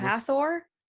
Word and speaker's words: Hathor: Yeah, Hathor.